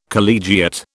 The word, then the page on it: collegiate: British English